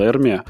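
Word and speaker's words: Hermès: Вот, мне вот, не, это правильно сказал сначала, Эрме. Эрме.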